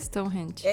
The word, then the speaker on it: Stonehenge: Stonehenge.